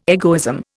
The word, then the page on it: egoism: Indian